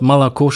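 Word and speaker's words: Malako (молоко): Wenn Sie das Wort Malako schreiben, mhm. das wird also Milch, das kann man sich ja noch leicht merken, aber es wird Moloko geschrieben.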